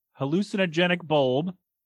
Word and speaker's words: Hallucinogenic Bulb: So it's Hallucinogenic Bulb.